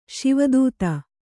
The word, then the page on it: ಶಿವತಾತಿ: Śiva tāti